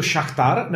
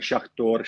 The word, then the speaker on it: Šachtar: Hele, ono to je vlastně jedno, protože ono záleží, jestli to vyslovuješ v ruštině nebo v ukrajinštině tak i šachtor, šachtar, takže záleží. A ukrajinsky jako to správně jak? Uh, šachtar. Šachtar. do šachtar Doněck.